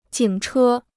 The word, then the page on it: 警车: jǐng chē